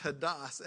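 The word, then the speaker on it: hadas (ὁδός): The Greek word found here is hadas. It's funny that it doesn't pronounce the way we would think because there's O's in there. But it's pronounced hadas, and it's translated into English as way 83 times, as journey 6 times, and as highway 3 times.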